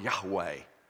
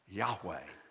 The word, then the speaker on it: Yahweh: And basically, we think it's pronounced close to the word Yahweh. Yahweh. And so it's translated, I am. But in the Hebrew, Yahweh, Y-H-W-H.